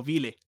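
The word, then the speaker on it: Wille: No, que en, en, en ¿cómo que se llama en Evangelion en, en, en los reveals, lo pronuncian como Vile.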